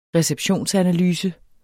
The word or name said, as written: receptionsanalyse